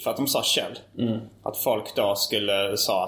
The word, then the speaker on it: Kjell: Logotypen är ett snäckskal, och på engelska så heter, uttalar man snäckskal Kjell, inte Själv.